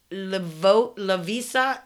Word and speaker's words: Lovisa: Lovisa, L-O-V-I-S-A. How would you say that? Lovisa? – Yeah.